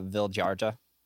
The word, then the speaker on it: Vildhjarta: Vildharta? Vildharta? – Yeah, Vildharta. – V-I-L-D-H-J-A-R-T-A.